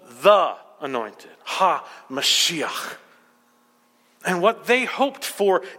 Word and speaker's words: Mashiach: It's a Hebrew word we should all know and know how to pronounce and impress our friends at work with. It's the word Mashiach. Mashiach.